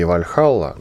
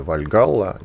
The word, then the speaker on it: Вальхалла: Так вот, там а есть такой сериал, называется он «Викинги Вальхалла», ну или «Вальгалла», неважно, как вам удобнее произносить.